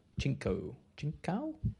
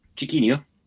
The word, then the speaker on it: Chiquinho: Clean bill of health, except for Chinkau? Right? – Chiquinho.